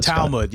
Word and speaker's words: Talmud: Talmud, Scott? – Yeah.